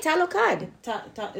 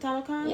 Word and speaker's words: Talokan: I think that's how you say it, Talokan. – Is it Talokan? – Yeah.